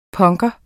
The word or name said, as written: punker